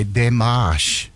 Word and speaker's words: démarche: I thought I knew this word, but I've been mispronouncing it. News of a United States diplomatic note. Diplomatic notes are known as a démarche. It's a French word, which means approach.